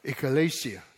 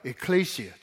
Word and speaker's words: ecclesia: 最近很多人在谈这个字，叫做 ecclesia，这是一种 pronunciation；一种叫 ecclesia，这是另外一个，这是希腊文。